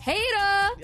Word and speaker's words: Hader: I said his name wrong a couple minutes ago, and BTS Butter is going to remind us always how to say it. Hater!